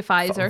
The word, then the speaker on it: Pfizer: So, yeah, but I actually, I did not, well, coincidentally, like I said to you, I had just had my second Pfizer. – Yeah. – How do you say it? – Your Pfizer. – Pfizer, my second jab for COVID.